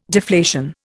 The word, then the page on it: deflation: Indian